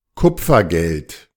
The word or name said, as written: Kupfergeld